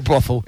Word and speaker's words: brothel: The TH, I was doing an F, you know, brothel. – A brothel. – Yeah. – Yeah, no, it's brothel.